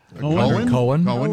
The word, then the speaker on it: Cohen: Cohen. Yeah, you. – This gets confusing because no, not when it's spelled C-O-H-E-N. – It's definitely Cohen.